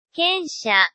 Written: kensha

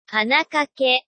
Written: hanakake

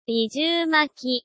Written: nijuumaki

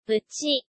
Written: buchi

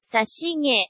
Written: sashige

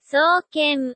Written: souken

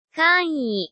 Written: kan'i

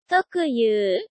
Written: tokuyuu

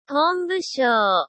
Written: honbushou

